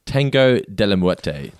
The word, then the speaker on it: Tango de la Muerte: So Marge and Lisa go to see Tango de la Muerte. Did I say that right? – You did indeed, and with a very nice Latino spin on it.